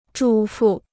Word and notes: 主妇: zhǔ fù

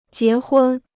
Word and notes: 结婚: jié hūn

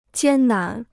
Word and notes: 艰难: jiān nán

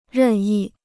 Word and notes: 任意: rèn yì

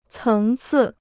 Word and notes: 层次: céng cì

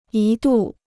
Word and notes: 一度: yī dù

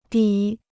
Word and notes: 底: de

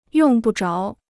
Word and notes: 用不着: yòng bu zháo

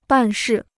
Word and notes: 办事: bàn shì

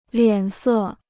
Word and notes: 脸色: liǎn sè

